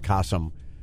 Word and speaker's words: Qasim: I think it's with a Q, but it's pronounced Qasim. So it would be Q-A.